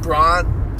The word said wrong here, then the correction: Branagh: Kenneth Branagh.